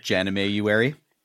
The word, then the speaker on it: Jamuary: Well, even though it is Jamuary, or however you want to pronounce that. – It's pronounced Jamuary.